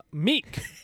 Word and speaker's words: MECC: Meek. That's spelled M-E-C-C.